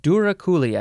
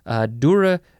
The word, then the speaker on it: douroucouli: Duraculi, I think that is how you pronounce it. It is spelled D-O-U-R-O-U-C-O-U-L-I. Duraculi.